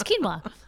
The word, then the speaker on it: quinoa: But where's the emphasis? – Just quinoa. The emphasis on the wrong syllable. – Exactly. – Are you nervous? No, it's quinoa. Just quinoa.